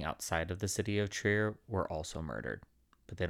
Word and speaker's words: Trier: Trier, the city of Trier, I believe is how you pronounce it.